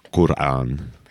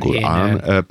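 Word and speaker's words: Qur'an: Megfigyelte, hogy a Korán kiejtésekor is szünetet tartanak az R és az A között,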